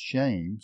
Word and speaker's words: shem: And so this word for name there actually is pronounced shame, spelled S-H-E-M in the Hebrew.